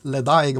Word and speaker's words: Ledaig: Men det kommer ju en annan originalbuteljering eller destilleributeljering från Tober Morris rökiga variant och då är det ju, ja, jag lyckas aldrig uttala det här riktigt som man ska göra, men någonting logic, även om det läses ledaig om man läser det lite mer svenskt tycker jag.